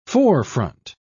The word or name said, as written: forefront